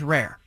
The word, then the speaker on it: rear: In certain sections of England, rear is pronounced and spelled as rare. – Okay. – When a horse rears up on its hind legs, you know, in those dialects, it can be acceptable.